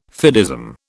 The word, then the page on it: fideism: British English